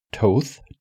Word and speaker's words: Thoth: But there's an Egyptian god that you would think is pronounced Toth, T H O T H.